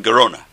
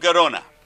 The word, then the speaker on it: Gerona: Rabbeinu Yonah from Gerona. It rhymes. Rabbeinu Yonah of Gerona.